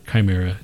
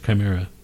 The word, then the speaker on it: Chimaira: Is that how you pronounce it? – I think it's Chimera. Yeah, I think it's Chimera.